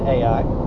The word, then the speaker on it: Ai: Ai is how it's spelled. Some people pronounce it Ai.